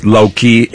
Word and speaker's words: Lokee: And that's spelled S-E-I-L-E-S. It's Low Key, L-O-K-E-E.